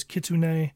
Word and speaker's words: Kitsune: I believe the real pronunciation of it is Kitsune.